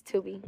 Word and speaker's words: Tubi: I said it right, Tubi? – Yes, Tubi.